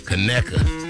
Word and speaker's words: Conecuh: Kanuka. And I'm sure somebody going to say, you saying it wrong. No. – Yeah, because it don't sound quite. – No, it's that Kanuka sausage. It's called, Conecuh.